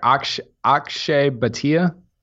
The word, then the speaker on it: Akshay Bhatia: I can't ever say his name. Ashkai Do you remember the guy that played in the Valspar? – No. – You said his name, so I didn't have to. – Did I say it correctly? – Yeah, the left-hander, Akshay Bhatia.